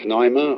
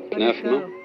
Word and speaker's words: pneuma: You may pronounce it pneuma or pnefma.